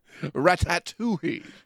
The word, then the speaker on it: Ratatouille: You know, I thought that was a ratatouille. Am I saying that right? Ratatouille? – Ratatouille.